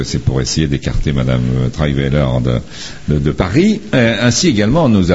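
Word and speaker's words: Trierweiler: Ainsi, nous apprenons que Madame Valérie Trahivelaire, hein, c'est ça. – On l'a bien prononcé en plus.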